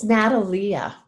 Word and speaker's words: Natalia: Have I pronounced your name correctly? – Actually, it's Natalia. – Oh, it's Natalia. Okay. – Which is a very unusual spelling, and I just want you to know nobody says it correctly, and I forgot to tell you in advance.